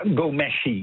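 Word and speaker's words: Ghomeshi: Ja, een grote mediapersoonlijkheid die van de ene op de andere dag van de zender werd gehaald was Gian Gomeshi, zeg ik het goed? – Ja, Gomeshi, oorspronkelijk uit Iran, dus een Iraanse naam.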